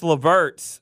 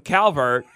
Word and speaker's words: LeVert: I completely butchered Karis LeVert's name, and I called him Calvert.